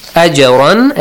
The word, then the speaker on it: أَجْرًا: أَجْرًا actually is pronounced with an echoing sound.